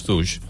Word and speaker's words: Stooges: Com, per exemple, aquesta de Stooges, no sé si es pronuncia Stooges. Així. – Sí, els Stooges.